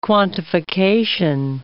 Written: quantification

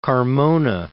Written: Carmona

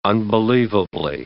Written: unbelievably

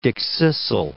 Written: dickcissel

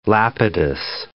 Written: Lapidus

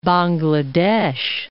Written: Bangladesh

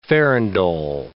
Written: farandole

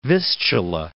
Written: Vistula

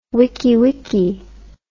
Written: wikiwiki

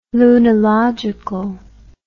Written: lunilogical

